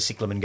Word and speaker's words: cyclamen: And then when the cyclamen go down, the euonymus is still there to do its thing. – Are the cyclamen the same as cyclamen? – Yes, it's just that I pronounce it properly.